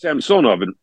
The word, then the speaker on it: Samsonov: So however you say your name, like, so Ilya Samsonov in Toronto. – Mm-hmm. – It's Samsonov in Russian. In Russian, the middle syllable is accented.